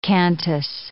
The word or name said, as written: cantus